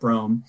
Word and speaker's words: Brougham: And by the way, it's spelled weird, but it's pronounced like Rome, but Brougham.